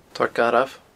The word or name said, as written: torr garbh